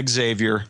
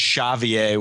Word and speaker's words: Xavier: It seems like on the East Coast they all say Xavier, and then in the Midwest you hear it with the Z sound. I always kind of like the way it's pronounced in Brazil is Xavier, which I think is a little bit more Xavier.